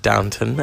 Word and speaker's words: Downton: You have to say it right. Downton Abbey.